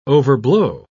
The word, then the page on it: overblow: British English